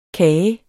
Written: kage

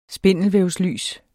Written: spindelvævslys

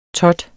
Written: tot

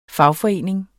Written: fagforening

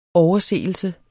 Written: overseelse

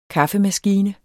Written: KAFFEMASKINE

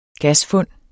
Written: gasfund

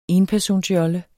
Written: enpersonsjolle

